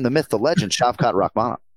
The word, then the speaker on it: Rakhmonov: Yeah, so we're going second syllable here, Shavkat Rakhmonov. So not Rakhmonov, and hopefully that helps everybody with the navigation this weekend.